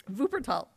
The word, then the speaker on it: Wuppertal: He was a classroom teacher of German in Iowa City and in Wutapol. Close enough? If I'm saying it right, Wutapol, Germany? – Wuppertal.